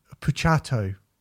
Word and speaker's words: Puciato: Greg Greg Puciato. Puciato. I've been told I said his name properly.